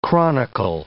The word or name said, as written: chronicle